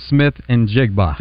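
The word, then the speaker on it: Smith-Njigba: Smith and Jigba. – Thank you. I can never remember if the N is silent or not.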